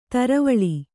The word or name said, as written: ದ್ರಾವಿಳ